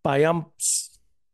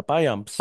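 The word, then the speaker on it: Payamps: mira, pero detrás del hombre tienen a Joel Piamps. Así que se pronuncia, Piamps.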